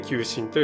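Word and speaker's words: 旧神: まあ、求心といっても、薬にね求心というのがあるんですがそれとは全く関係なくてですね漢字で書くと旧あの一本線に火の 9 ですねに、えっと、神と書いて求心と読みます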